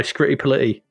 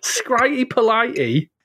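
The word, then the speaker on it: Scritti Politti: You have to say it like that. Skritti. Skritti Politti. You can't say it any other way. – Yes, because it can't be Skritti Politti, can it? – If you were doing this in like Give Us a Clue, rhymes with fighty. Politti? – Bitey. – Rhymes with bitey.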